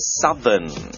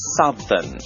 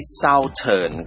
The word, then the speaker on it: southern: southern ออกเสียงว่า southern ไม่ใช่ southern ครับ southern